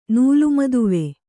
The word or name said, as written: ನೂಲುಮದುವೆ